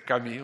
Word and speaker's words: Camus: Pero a mí me preocupa, ya que se habló aquí antes de los 60 y después de los 60, Albert Camus, se escribe Camus, pues se dice Camus,